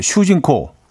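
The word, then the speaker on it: 슈진코 (主人公): 똑같은 한자어로 일본, 어, 일본어로는 이제 발음이 이제 슈진코.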